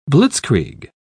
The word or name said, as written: blitzkrieg